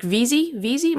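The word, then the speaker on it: Veasey: And Representative Moore, I understand that this was one of the first pieces of legislation that you introduced, I think, along with Representative Mark Veazey, am I saying his name correctly? Yeah.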